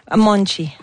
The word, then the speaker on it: Monte: On dit Monte? – Monte. – Monte, ça s'écrit M-O-N-T-E pour les intéressés.